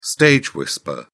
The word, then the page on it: stage whisper: British English